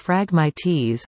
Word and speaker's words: phragmites: In 2005, invasive phragmites, pronounced phragmites, were recognized as this country's worst invasive plant by Agriculture and Agri-Food Canada.